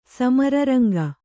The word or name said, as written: ಸಮರರಂಗ